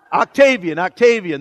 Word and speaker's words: Octavian: Octavio, you don't know how to say it either. Octavian, that's it. Yeah, that's how he said it. Yeah, you're right.